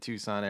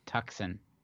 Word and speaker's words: Tucson: Hey Dude was filmed at the Tanqua Verde Guest Ranch in Tucson, Arizona. And it's pronounced Tucson. – I'm sorry, Tucson, Arizona.